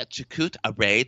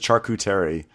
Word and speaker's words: charcuterie: Like, I was making a chakut array tray.